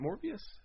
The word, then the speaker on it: Morbius: Is it Morbius? Am I saying that right? – Yeah.